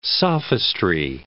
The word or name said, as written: sophistry